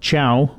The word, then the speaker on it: Cao: The email address is Tate, that's T-A-T-E, at Chow, which is spelled C-A-O, at usask.ca.